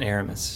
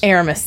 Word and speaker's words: Aramis: Porthos, Athos, and Aramis. – Aramis, thanks. Okay. Focus fire on that one. I think his name is Aramis. And he's like, actually, it's Aramis.